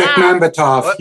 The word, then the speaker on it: Bekmambetov: Timur Bekmambetov. Oh, that was horrible. – Wow. Bekmambetov. You were damn close.